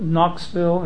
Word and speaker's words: Knoxville: There's a city called Knoxville and spelled with a K. It's not called Knoxville. The K is omitted because it's silent.